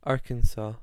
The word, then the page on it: Arkansas: American English